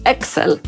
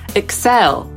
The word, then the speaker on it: Excel: Wspaniałym przykładem są też nazwy programów z rodziny Microsoftu, czyli, tak jak my po polsku mówimy, Word i Excel. Tymczasem po angielsku przecież mówimy Word albo Word i Excel, czyli akcent na drugą sylabę.